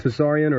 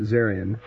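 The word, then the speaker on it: Tsarion: Now, have I been pronouncing your name wrong the entire interview? – No, you got it right. It's with a T, T-S-A-R-I-O-N for people. – So is it Tesarian or is it Zarian? Oh, both.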